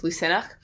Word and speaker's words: Lughnasadh: Yeah, so Lammas is the Christian name, but the Irish pronunciation is Lucenach, which is spelled differently. So it's Lughnasadh.